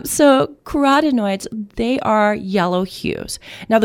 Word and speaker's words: carotenoids: And I'm so happy that I did, because I have always thought the way this word is pronounced was carotenoids. – Yeah, it's, I probably would have said it like that. – Turns out it's carotenoids.